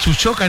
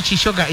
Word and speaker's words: Chuchoca: Es Chuchoca, no Chichoca.